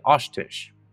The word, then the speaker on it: Oshtish: The reason being, I found that I was really mispronouncing this person's name. Directly translated in Crow, it means finds them and kills them, but her name is actually Oshtish.